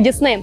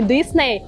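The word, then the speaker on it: Disney: Уверена на сто процентов, что каждый из вас в детстве смотрел мультики Дисней. Так вот, в Америке правильно говорить не Дисней, а Дисней.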